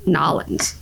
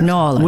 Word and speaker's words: New Orleans: New Orleans. – How do you say it like in conversation? – New Orleans. But if you live there, it's like Nolens. Yeah. Nolens.